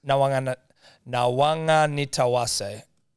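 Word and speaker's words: Nawaqanitawase: Nawanganitawase. Nawanganitawase. – It's a fucking pretty good crack, mate. – Because Q's are pronounced like NG in Fijian, I believe.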